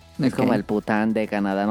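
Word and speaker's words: poutine: el Pután. No, es que hasta hay memes porque se, parece, o sea, se escribe como Putin, como Vladimir.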